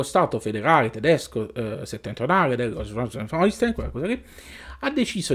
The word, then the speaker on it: Schleswig-Holstein: Come si pronuncia? – Schleswig-Holstein. – Hai sentito? – Schleswig-Holstein.